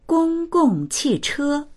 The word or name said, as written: gong1gong4qi4che1